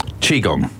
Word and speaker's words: Qigong: Qigong.